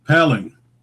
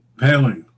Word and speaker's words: Paling: Our next guest is Suzanne Paling. I hope I pronounced it right, Suzanne. – You did. Paling. – Paling.